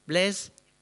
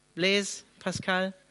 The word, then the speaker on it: Blaise Pascal: Wie spricht man es richtig aus? Blaise, Blaise Pascal.